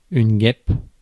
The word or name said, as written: guêpe